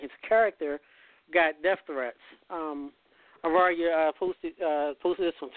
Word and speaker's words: Ariya: Ariya, yes, Ariya.